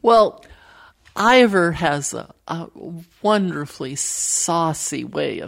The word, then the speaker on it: Ivor: Can you explain to our listeners how the co-author of your book, Ivor, was it pronounced? – Ivor. – Ivor. I keep saying that.